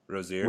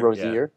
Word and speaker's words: Rozier: The Hawks would have to take back Lee Jerebko and Terry Rozier, or however you pronounce his name, Rozier. Rozier. Yeah.